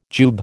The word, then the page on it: jube: American English